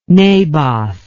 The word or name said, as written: Naboth